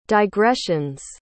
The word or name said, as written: digressions